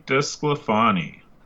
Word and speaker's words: DeSclafani: Today we've got Anthony DeSclafani.